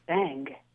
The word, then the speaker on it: Bingue: And my last name, B-I-N-G-U-E, is actually pronounced Bang. Very French.